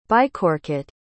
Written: bycoket

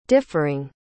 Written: differing

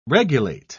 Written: regulate

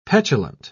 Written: petulant

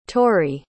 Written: torri

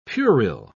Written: puerile

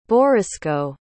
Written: borysko

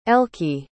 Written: elkie

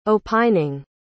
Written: opining

